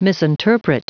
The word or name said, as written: misinterpret